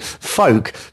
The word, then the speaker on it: FOLC: And in the second half of the show, we've got music from a newish label called, now, it's spelled F-O-L-C, folk, but I'm pronouncing it folk.